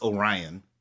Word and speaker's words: Orion: Omarion. Orion. Whatever it is. So I thought it was cool that she actually was like, hey, no, it's Orion. Mm-hmm. Say it right.